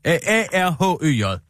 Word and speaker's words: Arhøj: Ahoy. A-H-Ø-J. Ja, lige et øjeblik. Tak. Måske udtales det Ahoy. Nå, A-R-H-Ø-Ø-J. Ø-Ø-J? – Nej. A-R-H-Ø-J. – Ø-J, ja, Arhøj.